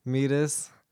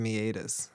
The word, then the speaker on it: meatus: Nick, our patron, said that he laughed when I said meatus. But I didn't know why until later. It's because it's pronounced meatus.